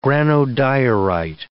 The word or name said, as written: granodiorite